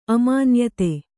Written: ಅಮಾನ್ಯತೆ

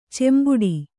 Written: ಚೆಂಬಟ್ಟೆ

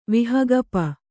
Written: ವಿಹಗಪ

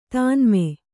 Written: ತನ್ಮೆ

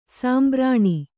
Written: ಸಂಭಾರಣೆ

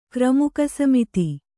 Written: ಕ್ರಮುಕಸಮಿತಿ

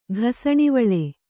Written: ಘಸಣಿವಳೆ

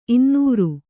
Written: ಇನ್ನೂರು

